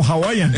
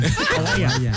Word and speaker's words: Hawaiian: Hawaiian Pizza Day. – No, Hawaiian. Hawaiian. Al principio lo dijo bien.